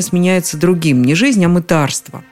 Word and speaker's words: мытарство: Обратите внимание на ударение. На «ы» оно приходится только в слове «мытарь». Во всех остальных переходит на «а» – «мытарить», «мытариться», «мытарство».